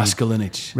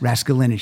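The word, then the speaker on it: Raskulinecz: And I never know how to say his last name. – Raskolnikov. – Raskolnikov.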